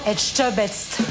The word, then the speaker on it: Etchebest: Etchebest. The... – Voilà. – Bonjour Etchebest. – Nous sommes donc avec le chef Philippe Etchebest. – Et ben voilà, notre public le prononce bien.